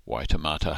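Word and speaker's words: Waitematā: but the harbour on which Auckland is based is the Waitemata Harbour, and we would pronounce it Waitemata Harbour.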